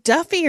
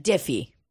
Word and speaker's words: Diffie: Is it Duffy or Diffie? – Diffie. D-I-F-F-I-E. Just like you think it's spelled. Stupidest possible way.